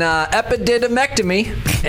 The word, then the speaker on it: epididymectomy: Yep, it's called an epididymectomy, and... – you can't even say it. – Epididymectomy. That's how you say it.